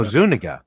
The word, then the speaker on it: Zuniga: No, Zuniga. Because on the commentary, Mel Brooks actually said how he had messed up the pronunciation, and then she had to correct him on the correct way to say it.